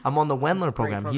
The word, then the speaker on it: Wendler: He's like, I'm on the, you can correct me if I'm saying this wrong, the Wilder program, the 531 method. – Wendler? It's Wendler. Yeah, there you go.